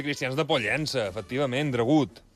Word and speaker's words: Dragut: I doncs, els llatins, per referir-se a Turgut, acabaven pronunciant dragut, clar. Allà es passa que quan passes d'un idioma a l'altre, pues, dragut, dragut, dragut, dragut, que es pronunciaria, a més, amb molt de temor i amb un temor creixent per tota la cristiandat.